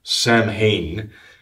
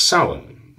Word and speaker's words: Samhain: It's spelled Samhain, but it's not pronounced that. You know, it's Samhain, the Feast of Samhain.